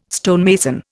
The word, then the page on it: stonemason: Indian